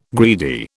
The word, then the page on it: greedy: British English